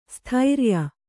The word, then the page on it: ಸ್ಥಿರಾಯು: kannada